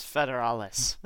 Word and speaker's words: federales: He's mocking your pronunciation. – Yeah. – It's federales.